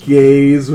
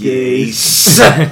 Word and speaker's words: Gase: It's not Gaze with a limp wrist. It's Gase.